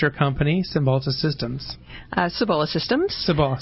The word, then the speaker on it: Cibola: So say it correctly again for us. Cibola Systems. Cibola. There we go.